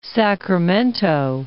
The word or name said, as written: Sacramento